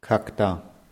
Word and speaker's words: когда: Когда. – Hm, wird wieder mit O geschrieben, aber A ausgesprochen.